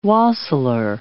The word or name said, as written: wassailer